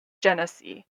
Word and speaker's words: Genesee: G E N E S E E. Genesis. Genesis. Anyway, I'm going to go with Genesee. I believe that's how you say it.